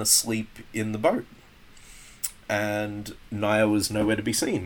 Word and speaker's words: Naya: Would you say Naya or Naya? – Naya Rivera. – It is Naya.